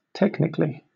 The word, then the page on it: technically: British English